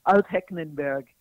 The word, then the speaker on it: Altenburg: I mean, you started with the right letter, but it was it's Altenburg. There's extra syllable, I think, in there. Yeah. So it's a long tongue twister.